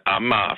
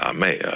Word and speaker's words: Amager: Altså hvis vi siger Amager for eksempel i Københavnsområdet, så vil mange jyder vil jo aldrig drømme om, at det skal udtales på den måde. De siger Amager eller noget andet, nogenlunde mere neutralt, ikke?